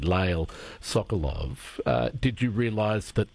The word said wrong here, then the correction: Lael: Well, it's Lully, by the way. I get the confusion. – Lully. – Yeah, Lully.